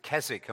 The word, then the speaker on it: Keswick: And I noticed that there is a street name on the northern side of Tilsonburg, spelt K-E-S-W-I-C-K. And in England, you pronounce it Keswick, and I'm assuming that you might say Keswick here or something to that effect.